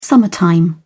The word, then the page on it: summertime: British English